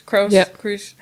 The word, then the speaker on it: Kroos: Toni Kroos, Kroos, how do you say in German? – Kroos, Toni Kroos, and Felix Kroos.